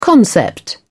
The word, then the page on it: concept: British English